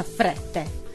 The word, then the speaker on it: Frette: Bravissima Martina, oh, mi è piaciuto anche la pronuncia Frette, perché io l'ho sempre detto così, scusa. E Martina, allora, visto che parliamo di Francia, io ho trovato questa canzone di Camera Obscura, French Navy.